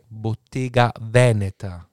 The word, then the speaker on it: Bottega Veneta: Bottega schreibt man nämlich mit zwei T und Veneta mit einem T und dann ist die Betonung nämlich anders.